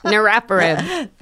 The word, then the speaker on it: niraparib: And there's something called, I'm going to butcher this term, but it's niraparib.